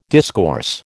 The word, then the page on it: discourse: American English